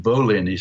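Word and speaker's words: Bollyn: And the other one was Solving 9/11, The Deception That Changed the World by Christopher Bolin. Is that right? – I think Bolin is how he pronounces it.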